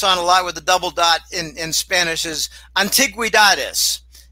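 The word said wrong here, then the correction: antigüedades: Antiquity, but they got over the U, which means add a syllable. Antiguedades. No antiguedades. Antiguedades.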